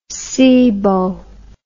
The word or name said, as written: seabow